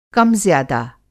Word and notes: कम-ज़्यादा: کم زِیاد; Hindi

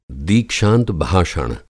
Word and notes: दीक्षांत भाषण: Hindi

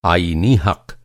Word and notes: आईनी: آئینی

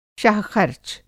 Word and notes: शाहख़र्च: شاہ خرچ

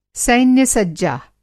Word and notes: सैन्य-सज्जा: Hindi

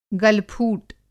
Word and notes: गलफूट: Hindi

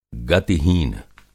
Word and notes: गतिहीन: Hindi

